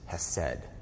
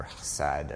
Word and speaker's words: hesed: Favor is hesed, or hesed, or H-E-S-E-D is how we spell it.